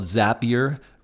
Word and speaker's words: Zapier: Wade is the CEO and co-founder of a company called Zapier, rhymes with happier, I discovered in the midst of doing the research.